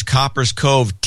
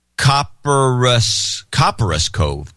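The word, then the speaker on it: Copperas Cove: James Pierce, Coppers Cove, Texas. Ooh. Hey, guys. The town is pronounced Copperous Cove.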